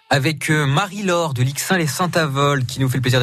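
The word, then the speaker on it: Lixing-lès-Saint-Avold: Je l'ai bien prononcé, on dit bien Lixin les Saint-Avold. – Oui, c'est ça.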